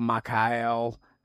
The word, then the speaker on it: Machel: One of the key figures was Samora Machel, or Machel, as some will pronounce his name.